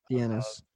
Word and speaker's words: Dienes: We do have Bay Village Police Department on record in the papers saying that they wanted to speak with Frank Denis, or Denise, if I'm saying his name right, D-I-E-N-E-S. – Dienis. Frank Dienis. – Yeah, Frank Dienis, and in relation to Amy's case.